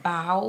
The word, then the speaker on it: bough: Now, when they say bow, what do they mean by bow? I'm thinking like bodily fluids. – Well, it's actually, and I'm not pronouncing it clearly enough, B-O-U-G-H, bowel without an L.